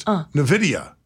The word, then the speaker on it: NVIDIA: The company is pronounced NVIDIA.